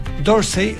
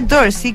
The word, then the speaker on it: Dorsey: ¿Cómo se pronunciará? – Dorsey, si fuese como el Museo Parisino, el Museo d'Orsay.